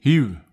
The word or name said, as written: hiu